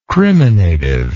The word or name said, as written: criminative